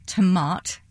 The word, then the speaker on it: Tomat: T-O-M-A-T. – Okay. – So it's a bit like tomato, but with the O off.